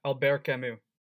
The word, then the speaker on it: Albert Camus: Is that how you pronounce it? – Yeah, Albert Camus. – I thought it was Albert Camus. – No, it's French. Albert, you don't pronounce the T.